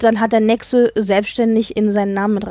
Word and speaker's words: Nexø: Hilfe bei der Aussprache Martin Andersen Nexe. Nexe? – Mm, Anders Nexe, ja. – Nexe, Dankeschön. Nexe ist die Insel, ähm, wo er aufgewachsen ist als Jugend, Kind und Jugendlicher.